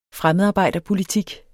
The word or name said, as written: fremmedarbejderpolitik